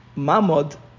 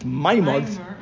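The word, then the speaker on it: Mamod (מעמד): Mem Ayin Mem Dalet. Mamod, or the way Ashkenazim pronounce it, Maimod.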